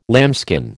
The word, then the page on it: lambskin: American English